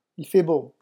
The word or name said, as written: il fait beau